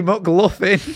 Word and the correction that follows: McLaughlin: It is pronounced McLaughlin.